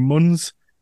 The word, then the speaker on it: Munz: Obviously, Jameson Foster was on a couple of weeks ago, and then his first time appearing is Andrew Munz.